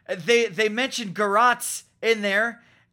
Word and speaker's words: garrote: And, you know, you just, okay, Garrote, it looks and sounds right. Apparently it's pronounced Garrote. – You are the smartest of the three, yes, you have it correct. Merriam-Webster confirms.